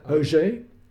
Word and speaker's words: Auger: Auger, French, A-U-G-E-R, Auger.